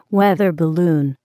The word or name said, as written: weather balloon